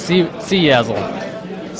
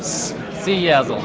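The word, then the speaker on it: CyaSSL: We're at Saya SSL, is that right? – We pronounce it C-Yazzle. C-Yazzle. – Okay, can you spell that? That's Charlie Yankee Alpha Sierra Sierra Lima.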